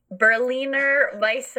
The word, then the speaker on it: Berliner Weisse: Berliner Weisse.